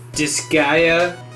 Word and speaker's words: Disgaea: Coming out Monday, January 30th, which is already gone, done, and said, Double Dragon 4, PS4, Disgaea 2.